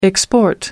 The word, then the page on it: export: American English